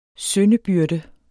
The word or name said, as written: syndebyrde